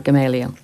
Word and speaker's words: Gamaliel: I think I've said that right, Gamaliel.